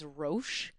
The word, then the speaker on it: Roche: His name is Roche, R-O-C-H-E.